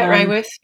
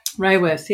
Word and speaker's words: Raworth: Raworth. How do I say her name? – Yeah, Kate Raworth. Yep. – Raworth, yeah.